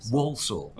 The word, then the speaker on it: Walsall: Walsall, is that how you pronounce it? – Walsall.